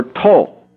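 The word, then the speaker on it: to (τό): It's a T and an O in the Greek words, in Greek letters, and is pronounced ta or to.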